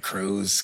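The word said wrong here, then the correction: Curze: Come on. – Which, it's Kurz.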